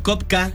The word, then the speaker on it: Copka: Estoy como Rob Kopka. Rob es mi nombre, Kopka mi apellido. Se escribe con C-O-P-K-A.